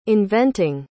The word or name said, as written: inventing